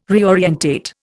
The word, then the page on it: reorientate: Indian